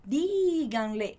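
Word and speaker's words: díganle: Então, aqui a gente tem uma palavra com 3 sílabas e a ênfase dela está na primeira sílaba já. Então, por isso a gente tem esse acento. Então, diganle, que é só para a gente dar essa ênfase da palavra mesmo.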